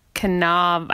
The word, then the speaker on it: Cannavale: So I know how to pronounce his last name. It's Cannavale. I have no idea how to say it, but there's no R.